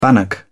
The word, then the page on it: bannock: British English